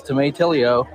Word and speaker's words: tomatillo: It's not tomato, it's tomatillo, green onions, and wait for it, oxacacanana cheese.